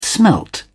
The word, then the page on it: smelt: British English